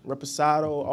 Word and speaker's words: Reposado: Reposado.